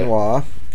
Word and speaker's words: quinoa: We We've got the eggplant canola with chicken. – Okay. Quinoa quinoa.